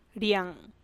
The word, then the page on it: ruynne didx: Teotitlán del Valle Zapotec